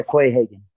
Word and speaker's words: Quayhagen: How do you say your name? – Yeah, Quayhagen.